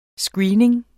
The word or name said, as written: screening